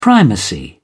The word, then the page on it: primacy: British English